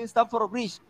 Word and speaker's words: Stamford Bridge: Stamford Bridge, ¿correcto? – Claro, Así se pronuncia.